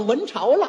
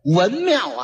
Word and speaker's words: 文庙: 你看见这么一会儿，咱咱咱们到了文朝了。一指这块匾，文庙啊，给念成文朝了，念错了一个。